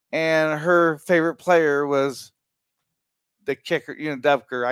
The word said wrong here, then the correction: Butker: I can't pronounce his last name. – Butker. – Yeah, Butker.